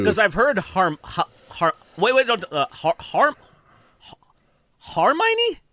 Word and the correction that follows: Hermione: Am I saying that wrong? – That's wild. – Harmony. – You messing it up is making me forget how to pronounce it. Actually, Harmony. – Hermione. – What is it? – Hermione. I was like, that's not how you say it.